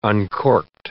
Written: uncorked

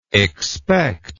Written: expecter

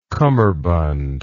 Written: kummerbund

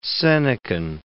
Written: Senecan

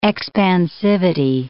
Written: expansivity

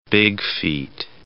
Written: bigfeet

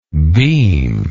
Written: beamlike

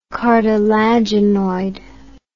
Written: cartilaginoid

